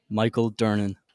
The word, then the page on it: Michael Durnin: male